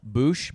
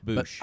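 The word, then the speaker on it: Boush: So Scott is not here. So we're going to have to rely on me for pronunciation. But the disguise Leia is wearing here, again, spoiler alert, is Boosh. – B-O-U-S-H-H.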